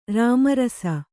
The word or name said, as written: ರಾಮರಸ